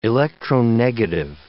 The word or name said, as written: electronegative